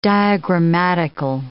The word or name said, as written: diagrammatical